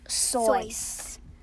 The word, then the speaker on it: Seuss: Dr. Seuss' name is pronounced Sois. – Like soy sauce.